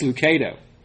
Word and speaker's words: Lucado: That's Lucado.